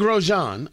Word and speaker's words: Grosjean: Meanwhile, they bring in Grosjean. I'm pronouncing his name right, right? – Yes, you are.